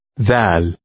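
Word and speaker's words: Dal (دال): Look at number 5, which starts with the Arabic letter Dal. – Its sound resembles the TH in the word the.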